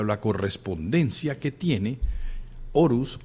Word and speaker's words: Horus: Y está también Horus, que realmente se pronuncia Auros, que es el dios del sol, dios de la luz, dios del nacimiento de la conciencia.